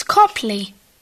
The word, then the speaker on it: Copley: It's Copley. – Peter Copley. How do you spell that? – C-O-P-L-E-Why? – Thank you.